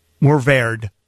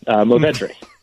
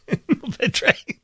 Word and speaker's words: Mourvèdre: T-E-S-S-E-L-L-A-E. This, it's a Côte-du-Roussillon, Grenache, Syrah, and Mourvèdre. Did I pronounce that right? – Close on that one. Mourvèdre. – Mourvèdre.